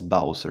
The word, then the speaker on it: Bowser: Nej, han heter Bowser. Aha. Ja, det är skitkult. – Jag vet inte, han kanske stavar det typ... Bauzer eller någonting, men det uttalas Bowser.